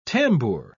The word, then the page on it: tambour: British English